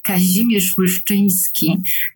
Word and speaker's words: Kazimierz Łyszczyński: So, uh, in fact, Kazimierz Łyszczyński is quite uh, difficult to pronounce for uh, no Poles, uh, but uh, he was also known uh, at his time as Kazimir Lyszyński, which is maybe a little bit easier.